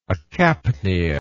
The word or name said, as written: acapnial